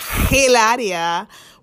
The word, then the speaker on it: Hilaria: her name is Hilaria, but she pronounced it Hilaria, which is not a Spanish pronunciation.